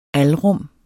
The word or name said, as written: alrum